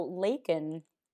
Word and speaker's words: Lakin: But you know what's funny is that, so Lakin has been mispronounced so many times as well, which is just like, we had this joke with Jody, and then we put it in our show, Hollywood Darlings, that people would constantly get my name wrong.